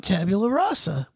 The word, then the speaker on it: Tabula Rasa: So it was directed by Jack Bender, who also directed the previous episode, Tabula Rasa, which I'm still mispronouncing. I'm not going to ask Future to help me with it anymore. I'm a big boy now. If I can't say that, then. – I mean, you do it like. No one would bug you. You're doing fine. – You got to roll your R. – There you go. There you go. – Sound more Latin.